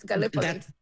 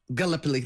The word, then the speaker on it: Gallipoli: I might correct you on the pronunciation of the Italian port we were at. It's Gallipoli. – Gallipoli.